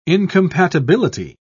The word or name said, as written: incompatibility